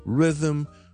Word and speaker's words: rhythm: 而家系 z z z，咁我哋先读咗 rhythm 个 r，第一个 syllable 系 r r，跟住 r 下咧，我哋保持个发声，但系我条脷尖咧就上咗嗰个位 rhythm 去呢个位，跟住再 zoom 翻出嚟就 rhythm rhythm rhythm， and that's how you pronounce the word rhythm。